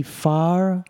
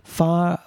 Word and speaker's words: Fajr: These are known as the FAR, that's spelled F-A-J-R, FAR 5 missiles.